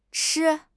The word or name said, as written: chi1